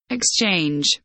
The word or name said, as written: exchange